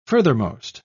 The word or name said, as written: furthermost